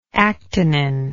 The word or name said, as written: actinin